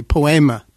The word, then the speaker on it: Poema: And am I saying it wrong? – Absolutely. Poema. – Poema, not Poema.